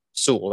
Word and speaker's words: Sewell: How do you say Sewell? – Sewell. That's what I thought.